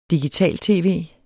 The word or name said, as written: digital-tv